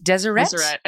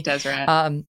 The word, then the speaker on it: Deseret: She's also editor at Ricochet, a contributing writer at, I never know how to say it, Deseret News? – Deseret, I think. – Thank you, Bethany. – Deseret.